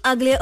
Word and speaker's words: aglio: Today, I'm making aglio olio with shrimps. – Who's ugly? – Or is it... You don't pronounce the G, is it? I don't know. We are not Italian.